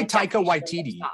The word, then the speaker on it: Waititi: I say Taika Waititi. I think it's YTD. I don't think it's Waititi.